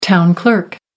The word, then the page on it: town clerk: American English